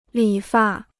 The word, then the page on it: 理发: lǐ fà